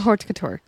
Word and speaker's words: haute couture: Is that not how you say it? – Just the way you attempted to say it. – Christian Dior haute. Christian Dior haute couture. Yes.